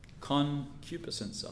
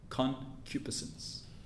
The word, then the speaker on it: concupiscence: Concupiscence. I'll spell it for you. C-O-N-C-U-P-I-Sense. S-C-E-N-C-E. Concupiscence.